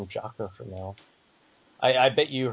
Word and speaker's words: Jaco: I was going to mention it in my awesome things, but I saw, like, Resurrection of F, and Jacko was definitely a highlight of that movie. Or I think they pronounce it Jocko.